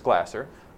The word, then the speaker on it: Glasser: somebody help me with the pronunciation, maybe it's Glaser. It's Glasser. We learn 10% of what we read,